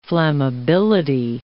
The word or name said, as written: flammability